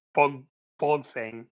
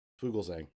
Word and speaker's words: Fuglsang: And then Fuglsang. – Fuglsang. – And then there's no there's no E in there in the middle. You're adding a syllable, I think. – Fuglsang.